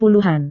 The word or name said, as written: puluhan